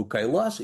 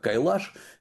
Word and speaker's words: Кайлас: что собираются специально взойти на гору Кайлас, или еще он произносится как Кайлаш, сделать вокруг нее обход, так называемая кора, для того, чтобы приобщиться к святыням, ну и самое главное, очистить сознание.